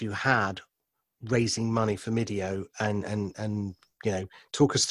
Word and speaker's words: Mydeo: It rhymes with video. It was Mydeo. It's my video.